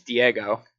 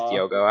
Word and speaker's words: Diogo: I think it's Diego, but it doesn't really make sense. Diego Jota, I'm pretty sure, but maybe Salah. – It is Diogo.